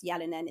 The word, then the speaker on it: Jalonen: But I'm under Kashi Jalanen, which is K-A-S-H-I. And then Jalanen, which is J-A-L-O-N-E-N, but it's actually pronounced Jalanen. It's a Finnish name. They don't pronounce their J's. They pronounce it with a Y.